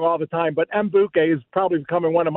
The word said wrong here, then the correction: Madubuike: my Madubuike.